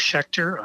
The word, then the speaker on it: Schecter: I am the best place, political stuff, at Cliff Schecter on Twitter. You know, Cliff's easy. There's two F's in there, and my last name is spelled S-C-H-E-C-T-E-R.